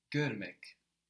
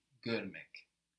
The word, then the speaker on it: Görmek: And here actually we learn another vowel in Turkish. Görmek is spelled G-O with two dots above the O. R-M-E-K. This is the last vowel we have to learn in Turkish. The last one. The vowel here is exactly like the vowel we have in English.